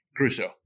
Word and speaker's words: Gruesso: Gruesso. Gruesso. Just like it looks.